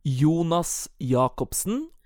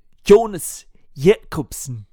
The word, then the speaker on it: Jonas Jakobsen: Jonas Jakobsen, or... with a pretend Danish accent, because it's Danish. Jonas Jakobsen.